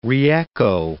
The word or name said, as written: reecho